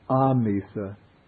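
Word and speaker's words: āmisa: Here the word is amisa, with a long A.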